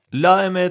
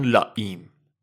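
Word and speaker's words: La Eme: لائمه تلفظ اسپانیولیشه، آمریکایی‌ها بهش میگن لائیم.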